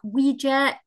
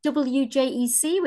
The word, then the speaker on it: WJEC: Um, oh, we have many pronunciations. Uh, the WJ, WJEC, Wejek, the W. have uh, heard all sorts of things. We are officially WJEC, which is the Welsh Joint Education Committee.